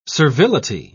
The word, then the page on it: servility: British English